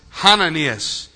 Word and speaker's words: Hananias: And in the Greek, there's a little thing that looks like an apostrophe that goes over the first letter. That means it has a heavy breathing before you pronounce it. It's the sound of the ruach, the breath. So it's Hananias.